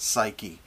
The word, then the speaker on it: psyche: It's spelled P-S-Y-C-H-E. We would pronounce it psyche, but it's psyche,